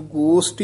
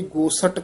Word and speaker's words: गोष्ट: इसनू गोष्ट ही उच्चारण करना है, कई गोसट कह देंदे हन, गलत है, गोसट नहीं कहना, गोष्ट कहना है।